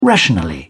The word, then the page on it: rationally: British English